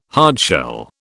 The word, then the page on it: hardshell: British English